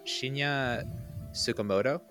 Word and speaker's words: Shinya Tsukamoto: And Bullet Ballet, I'm going to say this guy's name and try not to butcher it, is done by Shinya Tsukamoto.